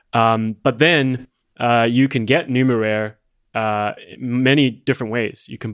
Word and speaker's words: Numeraire: It is technically called Numeraire.